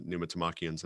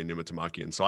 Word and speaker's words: Pneumatomachians: So I have to be able to say it so that I can I typically say Pneumatomachians, like I'm putting the emphasis on the second. – So that might be right.